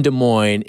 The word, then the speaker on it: Des Moines: I also saw, too, there was a great tweet out that Illinois versus Arkansas in Des Moines has to hold some sort of NCAA record between teams and location with silent S's. Arkansas, Illinois, Des Moines.